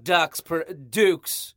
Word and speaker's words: Dux: Brooklinen is founded in 2014 by a husband and wife team, Vicky and Rich Fullup, and their dog, Dukes. They spell it D-U-X, and the name of the dog is Dukes.